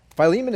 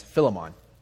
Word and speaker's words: Philemon: But Philemon is somewhat of a special book to me because when I first started going to church, I kept mispronouncing it as Philemon, like maybe some of you guys. And it wasn't until much later that I actually found out it was pronounced Philemon,